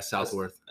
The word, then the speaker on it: Southworth: Yeah, Southworth.